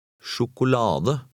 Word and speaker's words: Sjokolade: Try number 15, stressing the next to last syllable. – Sjokolade.